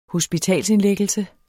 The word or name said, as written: hospitalsindlæggelse